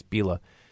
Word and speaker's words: Bila: Did Jedediah Bila, I said her name wrong for a long time, even though we're closer. I used to say Bila, but it's Bila.